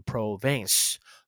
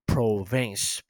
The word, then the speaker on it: Provence: 那普罗旺斯英文就念成 province。 Provence，普罗旺斯有去过吗？